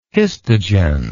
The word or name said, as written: histogen